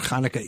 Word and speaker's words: Hanukkah: Rabbi, firstly, give us a brief reference of what Hanukkah is and what it is this year. – Sure. So Hanukkah, the Festival of Lights, those who don't have the C-H pronounced it Hanukkah, that's okay too, is a holiday on the Jewish calendar that emerged really about 2,300 years ago.